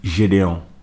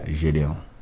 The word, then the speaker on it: Gideon: But the pronunciation Gideon, it's French, like pretty much. So the name is just Gideon, but in French, that's how you say it, Gideon.